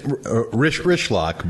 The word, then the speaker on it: Rychlak: That's Rischlack, but that's what Richard's called me forever.